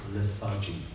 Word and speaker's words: lethargy: There's a spiritual lethargy. Well, how do you pronounce it? Lethargy. Lethargy. Lethargy. Not a liturgy. Lethargy.